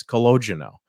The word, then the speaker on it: Calogero: It's Calogero. Calogero. So, see, I've been saying it wrong.